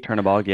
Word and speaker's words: Chernabog: Mother Gothel, Ursula, Hades, and Chernabog. I believe I pronounced that correctly. – Chernabog, yeah.